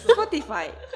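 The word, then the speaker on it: Spotify: スポティファイ。正しい発音でスポティファイ。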